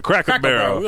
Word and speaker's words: Cracker Barrel: Crackle Barrel or Cracker? – No, Crackle. – Okay, yeah. I always go Cracker. – To Cracker. Cracker Barrel. – We're on Cracker Barrel.